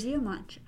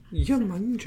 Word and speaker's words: Yemanja: It's spelled Lamanja, but they have it as Yamanja. – Yamanja. – So, like Yama.